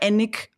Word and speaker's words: Alnwick: Ähm, aber es, also es wird Alnwick geschrieben, aber Annick ausgesprochen.